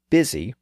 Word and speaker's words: busy: The modern word busy reflects this linguistic variation. It has the spelling of the west with the letter U, but it has the pronunciation of the north and east with the I sound.